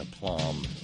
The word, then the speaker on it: aplomb: And the aforementioned folks, now including Roger in Oregon, will greet you with warm aplomb. That's a silent B in aplomb.